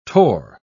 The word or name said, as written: torr